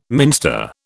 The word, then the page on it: minster: British English